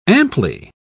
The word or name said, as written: amply